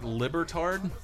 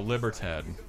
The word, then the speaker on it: Libertad: And am I saying this right? Libertard? No, Libertad. – Libertad.